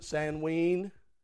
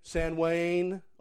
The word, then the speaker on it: Samhain: His name was, in different cultures, Samhain or Samhain. Some would pronounce it Sanween. Okay? Sanwain. All these different names.